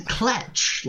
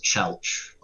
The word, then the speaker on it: Chelch: He said Kletch, like, there's no T in it, there's no N in it.